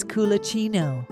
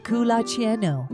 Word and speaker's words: culacino: It's culacino. This Italian word is pronounced culacino and has no direct English translation.